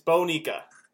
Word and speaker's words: Bonica: No, it's Bonica. Bonica. It's Bonica. – Okay. Bonica. – Bonica. It's so the only way I remember it, because I've been saying it wrong so long that now it sounds weird when I say it correctly. Just it spelled like Monica, but it's not pronounced like Monica. It's Bonica.